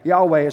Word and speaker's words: Yahweh: Yahweh is how we pronounce it now. We're not exactly 100% sure how to pronounce it because they never wrote it with the vowels in the word. So we're not 100% sure how we say it, but we say Yahweh, God's literal name in the Hebrew.